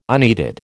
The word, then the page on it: unneeded: American English